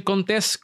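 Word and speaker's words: Contés: Mi nombre es Miguel Contes, con acento en la E y nos vemos en la próxima.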